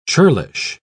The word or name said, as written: churlish